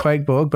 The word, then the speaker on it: Qvke Borg: Quakeborg, but I don't think it's uh, spelt the way that you would think it would no, be. No, it's it? spelt it's spelt with a V rather than a UA,